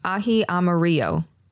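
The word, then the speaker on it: ají amarillo: With aji amarillo. Chili. Yes. I definitely said that right.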